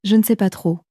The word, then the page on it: je ne sais pas trop: French